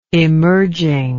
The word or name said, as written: emerging